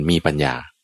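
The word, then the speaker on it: mepanya: มีปัญญา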